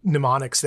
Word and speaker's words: mnemonic: I'm laughing because Isla and I had a discovery this week about how mnemonic was spelled, and we were really mad about how stupid silent letters in English are. Mnemonic is dumb and doesn't start with P, apparently.